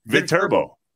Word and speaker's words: Viterbo: It was with the Cubs, but he went to Viterbo. Am I saying that right? In La Crosse? – Viterbo. – Yeah, Viterbo.